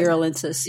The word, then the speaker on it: uralensis: and then there's the Chinese one, the Uralis. – Uralins. I always Uralensis. Get it wrong. – Uralensis. – Yes, thank you. I always say it wrong.